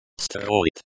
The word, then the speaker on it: строит: Строит. Строит. The O in the first syllable is pronounced like the O in hot. The I in the second syllable is pronounced like the E in C.